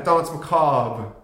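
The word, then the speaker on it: Danse Macabre: Yeah, for Dance Macabre. – Good pronunciation. – Thank you. I was waiting for you to acknowledge that. – The slight roll on the R.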